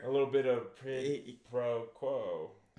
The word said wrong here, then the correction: quid pro quo: Did I say that right? – I'm sure you did. – Quid pro quo.